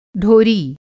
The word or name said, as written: ढोरी